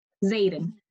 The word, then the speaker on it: Zayden: Zayden. Like Aiden with the Z.